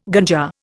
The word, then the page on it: ganja: Indian